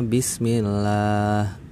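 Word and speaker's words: bismillah (بسم الله): tapi Hamzah wasol ini di kata bismillah ada di tengah kalimat. Jadi hamzah wasolnya nggak dibaca. Makanya dibacanya bismillah.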